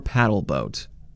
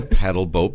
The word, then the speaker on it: pedal boat: Camp manager says locals every year ask whether it's pronounced pedal boat or paddle boat. – That's an often misconception. It's a pedal boat because you pedal like a bike, but it has paddles on it that propels it.